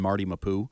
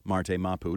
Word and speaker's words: Marte Mapu: maybe a fourth if you include our friend Marty Mapu or Marte Mapu, depending on your pronunciation of choice, that are box, bigger, banghead safeties.